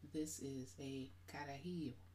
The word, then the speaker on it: carajillo: So I'm having a on the side note, I'm having a carajillo. Did I say it right? – Carajillo.